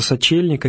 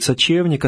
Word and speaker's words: Сочельник: В этот день в просторечии называется Сочельник, а вернее правильно говорить Сочевник, от слова Сочева, что значит отварная пшеница, которую вкушает знак вот, Страстного Поста перед этим великим праздником.